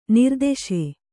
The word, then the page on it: ನಿರ್ದೆಸೆ: Kannada